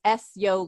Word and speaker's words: Ezjo: My website is S-Yo Life, but it's Hungarian, so it's not easy. It's spelled E-Z-J-O Life, and that's Hungarian for you good, S-Yo.